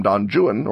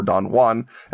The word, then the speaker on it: Don Juan: Originally driven by Byron's portrayal of Daniel Boone in the poem Don Juan, or Don Juan, as it is pronounced by people who haven't tried to read the poem out loud.